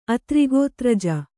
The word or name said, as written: ಅತ್ರಿಗೋತ್ರಜ